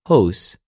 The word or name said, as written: ὡς